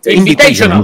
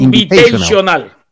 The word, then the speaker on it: Invitational: Invitational. – Muchas gracias. No, ya apúntenle las palabras que me queda difícil decir. – Invitational.